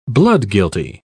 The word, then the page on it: bloodguilty: British English